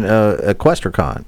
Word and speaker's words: EquestriCon: Well, let me ask Megan, EquestriCon. – Mm-hmm. – Now, I just learned how to pronounce that. – It's like Comic-Con.